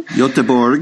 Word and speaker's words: Göteborg: Göteborg.